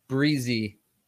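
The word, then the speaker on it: Bresee: Breezy.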